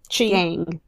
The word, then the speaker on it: Qi Yang: No, I spelled it. Q-I space Qi. – Yang. – Qi Yang? – Qi Yang?